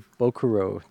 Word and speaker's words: Ikebukuro: It's a place called Silk Hat, and it is I'm not going to pronounce it correctly, but it's in the Ikebukuro district. I know I butchered it. – Ikebukuro district.